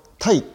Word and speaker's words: Ty: ーズというぬいぐるみがあったんですけれどもこれの話なんですねこれ僕全然知らなかったんですけれどもアメリカのタイという、えー、おもちゃメーカー、えー、アルファベットで TY と書いてタイなんですけれども、これ日本でも発売してるんですが、このタイ